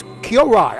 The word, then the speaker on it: Kyorai: How you spell that? – K-Y-O. – Yeah. – R-A-I is Kyorai. I'm sorry. Oh. I must humbly apologize to Kyorai. Oh. K-Y-O-R-A-I. Kyorai.